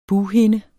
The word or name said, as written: BUGHINDE